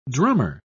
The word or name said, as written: drummer